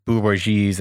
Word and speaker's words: bourgeoisie: All they did is, you know, replace the bourgeoisies. I can never say that word. How do you say that word? Bourgeoisie. Thank you.